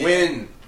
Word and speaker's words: Nguyen: Nugen. – Win. It's Nugen. – It's not. It's Nugen. It is. – It's Nugen. – Dude, it's you Nugen pronounce it Win. N-G-U-Y-E-N is pronounced Win.